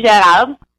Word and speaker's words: Gerard: the correct name, of course, is Stephanie Gerard. But here in America, you know, no one can pronounce it properly because of the accent, so I'm good with Stephanie Gerard. It's probably the American way to say it.